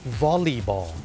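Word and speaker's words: volleyball: วอลเลย์บอลไปแล้วเนี่ยคนไทยเนี่ยเมื่อพูดถึงกีฬาวอลเลย์บอลเนี่ยออกเสียงว่าวอลเลย์ถูกไหมครับวอลเลย์บอลแต่จริงๆแล้วนะครับเจ้าของภาษาเนี่ยไม่ได้ออกเสียงว่าวอลเลย์บอลนะครับเขาออกเสียงว่าวอลลีบอลวอลลีบอลตัว LEY เป็นออกเสียงลีเนี่ยนะ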